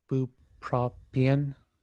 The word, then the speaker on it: bupropion: So this comic is the Escape from Bupropion.